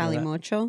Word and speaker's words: Calimocho: Calimacho. – Can you give us a pronunciation? – Calimocho. – Of that? – Calimocho. Mocho. But, you know, we did a little twist on it, made it a little fancier because we're fancy.